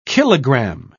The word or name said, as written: kilogram